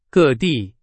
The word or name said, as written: 各地